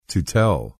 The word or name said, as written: To tell